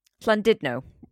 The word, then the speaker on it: Llandudno: Llandudno.